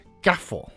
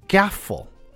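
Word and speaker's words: Carrefour: 那家乐福哈当然是来自法国的哈，家乐福这个法国的发音呢，其实叫做。 Gaffel 啊，Gaffel，这个是法国的发音啊。你知道这个家乐福 Gaffel 啊这个字呢，在法国其实它有原意，它原本是有它的意思的哈。猜猜看，家乐福的 Gaffel 这个法语是什么意思？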